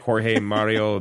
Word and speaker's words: Jorge: Elliot Cava as Cardinal George Mario. I know that's not how you say it. – George Mario? – Probably like Jorge Mario Bergoglio.